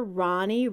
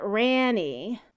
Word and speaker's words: Rani: Ronnie was named after Danny's father, Ron, but it's Rani. It's a Hebrew name meaning joyous song. And I don't know why they just didn't spell it with an O like your daughter. People are going to pronounce it Ranny.